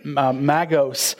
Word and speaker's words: magos: It's also magos, pronounced in the original.